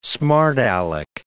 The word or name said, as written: smart aleck